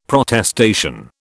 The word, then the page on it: Protestation: British English